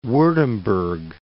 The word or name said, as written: Wurttemberg